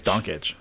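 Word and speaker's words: Dončić: It's Doncic.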